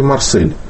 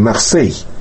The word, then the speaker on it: Марсель: Ведь мы по-французски произносим «Марсей». Да, и мы должны были бы писать с «и» кратким. У нас есть буква даже, так сказать, которая очень близко передает звучание. Нам нужно было бы писать «Марсей».